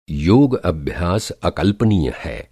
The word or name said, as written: अकल्पनीय